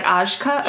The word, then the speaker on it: Ajka: A-J-K-A. So I thought it was going to be like Ajka or something, but I even went through the trouble to look up how to pronounce it and played the little audio clips where it's actual people from Hungary pronouncing it. – Okay. – And they're like, Ajka, but better than that.